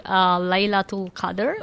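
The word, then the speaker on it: Laylatul Qadr (ليلة القدر): But this one is called, I pronounce it Laylat al-Qadr. Is that a good way to pronounce it? – Yeah, you're funny. It's actually Laylatul Qadr.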